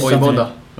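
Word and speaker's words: Vojvoda: How do you pronounce that surname? – Mercier? No. – No, no, it's Boyboda? Boyboda, or yes. – Boyboda, okay. It's V-O-J-V-O-D-A, if any listeners are wondering.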